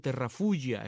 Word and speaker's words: Terrafugia: Vi este vehículo, este Terrafuya, es como se escribe, Terrafugia.